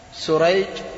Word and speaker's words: سريج: سريج بالسين المهملة وشريح بالشين المعجمة، سريج بالجيم في آخره وشريح بالحاء المهملة في آخره، تمام.